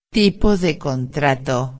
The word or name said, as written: tipo de contrato